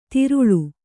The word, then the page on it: ತೆರಳಿ: Kannada